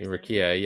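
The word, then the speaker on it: Rukiya: That's how you say her name? Is that right? – Right, Rukia, yeah.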